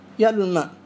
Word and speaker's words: يعلو: يعلو الماء هنا يحذف المد في اللفظ، لا تنطق الواو في اللفظ لكنها تكتب. هذا قوله فالمد حذف قم ويعلو الماء.